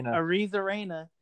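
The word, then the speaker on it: Arozarena: Arizarena.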